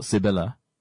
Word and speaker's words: Cibola: So, the seven cities of gold primarily were also known as the seven cities of Cibola, or Cibola, if, you know, just for proper pronunciation,